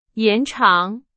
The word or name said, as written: 延长